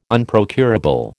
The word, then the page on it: Unprocurable: American English